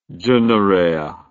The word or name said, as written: dunarea